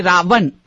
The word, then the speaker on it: Ravan: The reason we sometimes say Ravana or Rama is that in Sanskrit, unless you put a full stop at the end, it's sort of a something that closes out the vowel sound, the way you pronounce it in Sanskrit is Rama, Ravan.